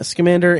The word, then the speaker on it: Scamander: Scamander and Goldstein.